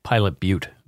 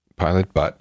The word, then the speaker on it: Pilot Butte: So she's from Pilot Butte. – Pilot Butte? – B-U-T-T-E. – Oh, okay. Pilot Butte, then. – Pilot Butte.